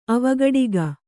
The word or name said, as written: ಅವಘಾಟಕ